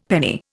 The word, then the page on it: penny: Indian